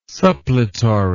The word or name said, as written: suppletory